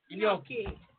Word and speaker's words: Gnocchi: It starts with a G. – Gnocchi. There you go.